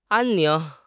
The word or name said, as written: 安宁